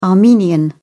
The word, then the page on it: armenian: British English